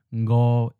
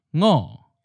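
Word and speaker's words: ngo (五): Also, another thing to note here is that the tone is different, with the colloquial pronunciation ngo is in a seventh tone, but in the literary pronunciation, we have a second tone, ngo.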